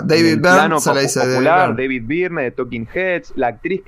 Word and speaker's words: Byrne: el que mejor pronuncia el mejor hispanohablante pronunciando el apellido del dibujante anglo-canadiense es Carlos Pacheco, que aclara una y mil veces que es John Byrne como si fuera quemándose, como la canción conocidísima de, Deep Purple.